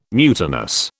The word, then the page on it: mutinous: British English